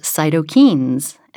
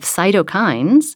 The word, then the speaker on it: cytokines: I had always thought that that word was pronounced cytokines, and in fact, I had had a doctor pronounce it that way to me once. But the macrophages in our immune system have cytokines that both are inflammatory and anti-inflammatory, and we need a balance of both of them. So now I know how to say it correctly.